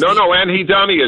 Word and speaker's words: anhedonia: Is it anhedonia? Is that how you say it? Cultural anhedonia is anhedonia is probably – anhedonic is the adjective. I assume anhedonia would probably be the noun. – Yeah, anhedonia.